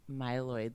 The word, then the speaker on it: myeloid: And Jared was recently diagnosed with AML, acute myeloid leukemia. I hope I'm pronouncing that right. Mm-hmm. – I think you got it right.